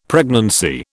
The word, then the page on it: pregnancy: British English